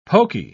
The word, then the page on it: pokey: British English